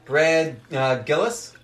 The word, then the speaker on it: Brad Gillis: Randy Rhoads, Brad Gillis.